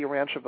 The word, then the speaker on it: Urantia: It's U-R-A-N-T-I-A. That's U-R-A-N-T-I-A, Urantia.